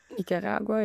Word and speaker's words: Nicaragua: Nicaragua, ja.